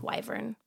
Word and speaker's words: wyvern: But it is wyvern because then I looked up the pronunciation, and it is, in fact, wyvern.